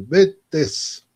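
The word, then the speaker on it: Btesh: Betz. Bezola sin vocal siguiente y Tess.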